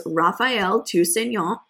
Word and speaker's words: Raphaëlle Tousignant: and this week we have one Woman of the Week, and that is Raphaëlle Tousignant. Ooh, killing it with the pronunciation. Oh, French pronunciation there.